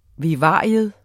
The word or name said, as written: vivarium